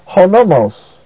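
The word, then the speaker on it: honomos (ὁ νόμος): ho also is a proclitic, so it is pronounced with the word nomos, so it's hoti honomos.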